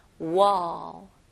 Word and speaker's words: wall: 발음은요, 이거는 공 할 때 ball하고 똑같아요. 볼 아닙니다. ball, 턱이 내려가는 느낌이에요. 그래서 다시 한번 같이 발음해 볼게요. wall, wall, okay? 그리고 L 발음은 혀가 윗천장으로 닿아야 되죠? wall.